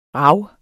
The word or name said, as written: rav